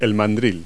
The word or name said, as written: chuck